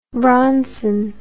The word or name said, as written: bronson